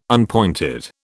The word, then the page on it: unpointed: British English